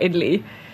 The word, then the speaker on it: idli: And you know, like, okay, so one more thing about idli.